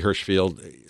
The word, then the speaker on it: Hirshfield: And so you could look up Howie Hirschfield, spell it H-I-R-S-H-F-I-E-L-D.